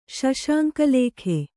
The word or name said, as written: ಶಶಾಂಕಲೇಖೆ